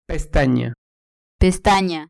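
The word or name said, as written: pestaña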